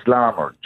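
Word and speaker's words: Larmour: Like the very minute you said that, because every commentator in the last few weeks have been saying Lamour, Larmer. It's Larmer, Jordan Larmer. – Larmer. – How you pronounce it.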